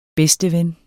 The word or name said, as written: bedsteven